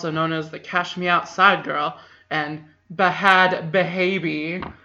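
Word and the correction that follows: Bhad Bhabie: And Nani is giving me a look like I am mispronouncing that. How is it pronounced? – Bad baby. – Bad baby.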